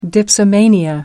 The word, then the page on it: dipsomania: American English